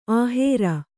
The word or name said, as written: ಅಹೇರು